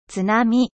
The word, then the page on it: tsunami: French